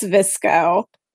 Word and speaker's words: VSCO: When I looked up VSCO, that's what you described came up. Yep. So I'm assuming it's Visco. – Yes. – Like Visco, but with V.